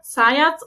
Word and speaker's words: Zayats: Wir haben heute zu Gast, und ich hoffe, ich spreche es richtig aus, ähm, die Marina Zayatz und den Thomas Herzberger von Schaffensgeist. Hallo. – Hallo. – Hallo. – Das hast du sehr richtig ausgesprochen.